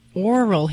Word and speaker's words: aural: And just for those that maybe can't hear real clearly, oral hematoma, it's spelled A-U-R-A-L, and it basically means swelling of the ear.